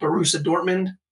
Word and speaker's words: Borussia Dortmund: There's a team called Borussia Dortmund, which is pretty good. A team called... – Pronounced perfectly, by the way.